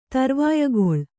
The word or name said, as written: ತರ್ವಾಯಗೊಳ್